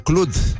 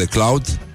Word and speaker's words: Cloud: El a mers uh, în fața Comisiei pentru Industrie, Cercetare și energie mentală, cred, uh, din Parlamentul European și el pronunță Clud în loc de Cloud și uh, în, loc, în română pronunță președinție, la fel ca Viorica Dăncilă în loc de președinție.